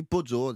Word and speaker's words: Pozzo: On dit Pozzo, c'est deux Z, ça se prononce comme ça.